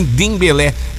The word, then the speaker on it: Dembélé: E também do Dembélé. Falei certo também, Dembélé.